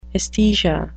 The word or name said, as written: esthesia